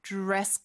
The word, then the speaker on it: dress: Is there a dress code? 이죠. 그리고 드레스가 아니에요. 하나의 단어로 dress 이렇게 발음해 주셔야 돼요.